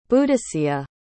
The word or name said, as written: Boudicea